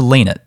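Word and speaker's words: Lienert: Anyway, Jared Leinert, the commentators say, but being the Brosser, there's a Leinert. – It's a Leinert, yeah. Being Brosser Germans, we know how to pronounce these names.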